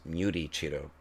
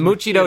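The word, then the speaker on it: Muichiro: His name is actually pronounced Muti Chito. Muti Chito. – Okay. Muti Chito? yeah. I, yeah. I thought it was Muchito.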